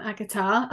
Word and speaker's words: Agata: Welcome, Agatha.